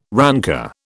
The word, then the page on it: rancour: British English